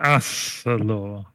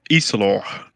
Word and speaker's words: Isalor: It should be an A, right? Like Asalor. Is that right? – A-E should be pronounced like an E, shouldn't it? – He's deeply offended. Yes, exactly. He's deeply offended by the A. It's an A, I think. – It's Isalor.